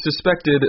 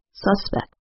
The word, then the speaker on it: suspect: You will also notice that the noun and verb are pronounced differently. The noun has stress on the first syllable, suspect. The verb has stress on the second syllable, suspect.